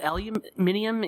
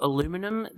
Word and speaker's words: aluminium: And, I've got one word for you here, aluminum. – That is different. That's an accepted thing. It's called aluminium in Australia. It's called aluminum. That's how it's said in America.